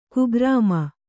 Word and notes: ಕುಗ್ರಾಮ: kugrāma

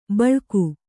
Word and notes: ಬಳಗ: baḷaga

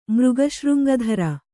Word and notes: ಮೃಗಶೃಂಗಧರ: kannada

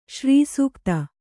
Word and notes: ಸರಸೋಕ್ತಿ: sarasōkti

